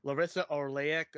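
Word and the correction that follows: Larisa Oleynik: or I forgot how to say her name. Can you say her name? – We've got Larissa Olenek as Bianca, the other sister here, and completely.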